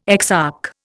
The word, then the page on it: exarch: Indian